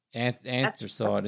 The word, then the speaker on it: anthracite: Astrate grey. – Yeah, anthracite, is it? Anthracite.